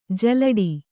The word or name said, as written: ಜಲ್ಲಡೆ